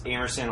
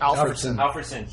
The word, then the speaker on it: Alfredson: I think Thomas Amerson, like, he just... Is that how you say his name? Alfredson.